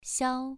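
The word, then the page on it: xiao: tone 2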